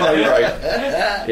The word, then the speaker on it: Moroder: Moroder.